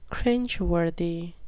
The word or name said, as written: cringeworthy